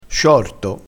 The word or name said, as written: sciorto